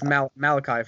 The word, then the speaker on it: Malachi: Obviously, Malak, I think you pronounce his name Malachi Flynn. – I think it's Malachi Flynn. – Malachi Flynn. Not Malak. Oh, yeah, that would make sense.